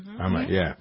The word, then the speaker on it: Ahmaud: Is it Ahmaud Arbery? – Ahmaud.